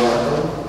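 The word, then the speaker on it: Larco: Yeah, it's Brian Largo, and it's L-A-R-C-O. – L-A-R-C-A? – C-O, Tom.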